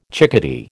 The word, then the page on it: chickadee: American English